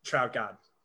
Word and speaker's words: Trautman: They traded their entire draft for Adam Trautman last year. – Yeah. – Wait. Three, yeah. Say his name the right way. – Trout God.